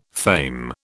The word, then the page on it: fame: British English